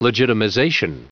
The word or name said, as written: legitimization